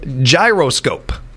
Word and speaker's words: gyroscope: gyroscope. It's broken down actually into three syllables. Gyroscope.